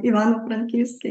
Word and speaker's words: Ivano-Frankivsk: So you settled in Ivano-Frankivsk. I hope I'm saying that correctly. – It's a long name, Ivano-Frankivsk.